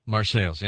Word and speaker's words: Marseilles: Yeah, it's the same as the French, but we butcher it in the United States. We pronounce it correctly. – You see, it really is Marseille, isn't it? – Yes, it is, but we pronounce it correctly. Yeah. Call it Marseilles.